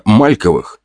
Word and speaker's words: Мальковых: Дела у рода Мальковых, ударение на «а», шли неплохо, но лишь из-за того, что граф работал как проклятый.